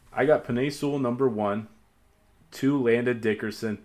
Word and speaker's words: Penei Sewell: Did I say that right? – Yeah, Penei Sewell.